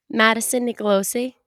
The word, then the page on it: Madison Nicolosi: female